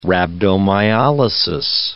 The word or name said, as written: rhabdomyolysis